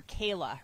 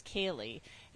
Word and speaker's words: Kaylee: Spoke to a representative from the court and actually said, you know, the judge mispronounced my sister's name. He called her Kayla. Her name is Kaylee.